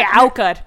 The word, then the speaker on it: Aukar: I'm like, okay, Aukar.